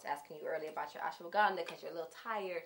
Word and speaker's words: ashwagandha: Ashwagandha. – Ashwagandha.